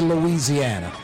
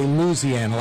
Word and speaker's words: Louisiana: Mario, isn't this storm kind of like Louisiana, Louisiana, like five syllables, in the city we say Louisiana. Outside of New Orleans, a lot of folks say Louisiana, like four syllables. We say Louisiana, five syllables.